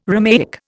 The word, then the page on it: Romaic: Indian